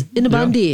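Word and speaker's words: innebandy: Is it called Inabandi? How do you pronounce it? – That's right. – Inabandi. Oh, it is Inabandi.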